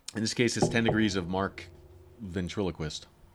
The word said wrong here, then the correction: Venturini: Did I say that right? – Venturini. – Oh, right. Sorry.